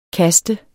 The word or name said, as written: kaster